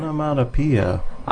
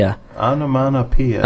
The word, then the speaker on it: onomatopoeia: He's been on here once with us before, and before when it was the Onomatopoeia show. Did I pronounce that right? I never pronounced it right. – Onomatopoeia. – I'm gonna pee. – Onomatopoeia.